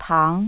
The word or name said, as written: tang